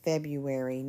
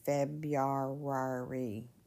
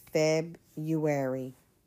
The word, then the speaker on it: February: Like February, that first R, we don't usually pronounce that. Okay. It's just February, not February. It's a weird word, I know. But just, it's February.